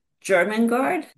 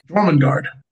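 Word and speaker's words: Jörmungandr: German guard? – Jormungandr.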